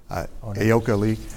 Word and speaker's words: Ayoka Lee: I should have given you a pronunciation guide on that. Ayoka Lee.